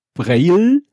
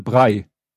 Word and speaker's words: Braille: ist, es wird nicht nur nicht Braille, es wird auch nicht Brei ausgesprochen, sondern Brei. Oh. – Also wieder Haferbrei. – Mhm. – Und das Witzige ist da doch wieder, noch, es wird immer witziger. Ich habe mir dann mal äh, eine englischsprachige Aus, Aussprache angehört und die war wirklich Braille.